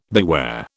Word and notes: beware: British English

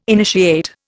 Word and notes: initiate: Indian

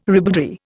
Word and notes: ribaldry: Indian

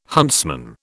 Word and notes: huntsman: British English

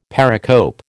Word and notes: pericope: American English

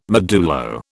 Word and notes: modulo: British English

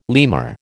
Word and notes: lemur: American English

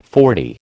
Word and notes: forty: American English

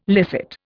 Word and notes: licit: Indian

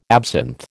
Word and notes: absinth: American English

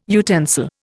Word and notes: utensil: Indian

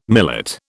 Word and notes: millet: British English

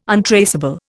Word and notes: untraceable: Indian

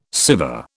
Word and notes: Siva: British English

